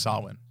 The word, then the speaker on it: Samhain: It's spelt Samhain. It's pronounced Samhain.